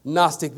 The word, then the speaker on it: Gnostic: Gnostic, the G is silent, but it's spelled G-N-O-S-T-I-C. G-N-O-S-T-I-C heresy. H-E-R-E-S-Y.